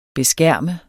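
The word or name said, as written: beskærme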